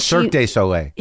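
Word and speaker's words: Cirque du Soleil: It's Circus, how do you pronounce it? Circus de Soleil or whatever. – Circus Soleil. – Soleil. – Yeah. – And Cirque de Soleil.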